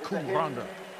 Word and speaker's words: Cwm Rhondda: You hear how it's pronounced, Cwm Rhondda.